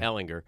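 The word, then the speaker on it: Ehlinger: But it's Shane B., who was at Texas and lost his job to the guy who's there now, Erlinger. Now I'll get his name wrong. – Ellinger.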